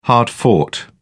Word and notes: hard-fought: British English

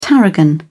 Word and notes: tarragon: British English

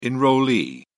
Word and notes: enrollee: British English

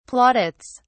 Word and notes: plaudits: British English

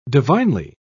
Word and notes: divinely: British English

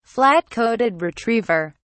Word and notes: flat-coated retriever: British English

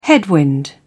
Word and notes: headwind: British English

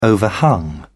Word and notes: overhung: British English

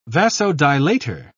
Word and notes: vasodilator: British English

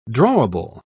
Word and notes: drawable: British English

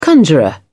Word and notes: conjurer: British English

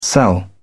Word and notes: cell: British English

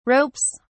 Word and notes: ropes: British English

